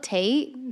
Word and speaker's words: Tate: I love Tate, the new artist. Am I saying that right? Yeah.